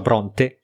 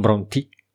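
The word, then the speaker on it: Brontë: Eh, che tra l'altro ho, ho, ho, letto di recente, che si, ho, ho, ascoltato di recente un video, eh, dicevano si pronuncia Bronte e non Bronti.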